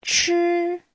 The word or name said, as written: chi1